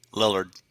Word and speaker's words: Lillard: Lillard.